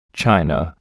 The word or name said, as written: china